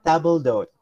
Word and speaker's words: table d'hote: First of all, yung table d'hote na sinasabi mo is technically a French term. So, this should have been pronounced as table d'hote.